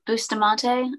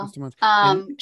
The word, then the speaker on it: Bustamante: So the next thing was a documentary called Killer Kids, and you played Alyssa Bustamante. Is that how you pronounce it? – Bustamante, I think so. Bustamante.